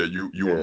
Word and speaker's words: Ewan: So, in the Birds of Prey, how you say his name? The OP1 dude, Ewan McGregor? – Yeah, you and McGregor.